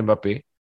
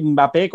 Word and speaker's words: Mbappé: Eh, hay gente que le llama eh, Mbappé, como la, M se oye muy poco, pero sigue sonando. Y otros que dicen Mbappé.